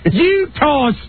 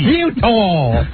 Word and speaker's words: Eutaw: Utah Street. – Utah. – Oh, that's right down by the Inner Harbor. – E-U-T-A-W. – It's spelled Utah Street. – Utah. – Utah.